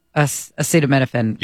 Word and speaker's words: acetaminophen: Acetaminophen.